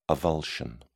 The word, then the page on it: avulsion: British English